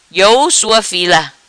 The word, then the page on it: Iou Sua Fila: Patuá